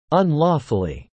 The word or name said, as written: unlawfully